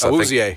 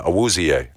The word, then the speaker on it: Awuzie: How do you pronounce it? The other free agent signing, Chidobe Awuzie. – From the Bengals? – Yes. – Awuzie. – Yeah, Awuzie. Thank you.